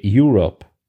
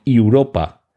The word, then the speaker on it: Europa: Europa, si lo habéis visto el capítulo en versión original, os habréis dado cuenta que en inglés no se dice Europe, sino Europa, porque es una palabra latina, el Europa de la luna, mientras que el Europa de continente sí, se escribe con una E al final en inglés y por tanto se pronuncia Europe.